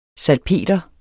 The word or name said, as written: SALPETER